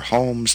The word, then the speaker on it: Hom: And it is Hom, dang it. There's no E. It's no amount of marketing will make me mispronounce the word H-O-M.